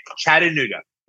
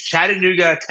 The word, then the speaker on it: Chattanooga: You're not saying it right. It's more D, not T. Okay, Chattanooga. It's Chattanooga, Tennessee.